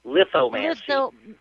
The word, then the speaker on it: lithomancy: But some of the people were saying they'd never even heard of lithomancy. Macy, I say it right, huh, Gary? But remember, it's like lithograph, lithomancy.